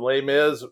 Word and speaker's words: Les Mis: And Les Mis rhymes with another one of your favorites, Grizz.